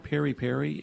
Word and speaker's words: peri-peri: Well, actually, you said peri-peri, and it's interesting. The British call it peri-peri.